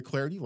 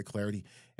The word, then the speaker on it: Clardy: You can follow, of course, me on Twitter at Troy Clarity. Last name is spelled C-L-A-R-D-Y, at Troy Clarity.